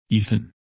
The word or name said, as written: Ethan